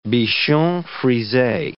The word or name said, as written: bichon frise